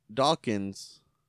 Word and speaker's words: Dawkins: And make Dawkins. Did I say his name right? – Yeah, Angelo Dawkins.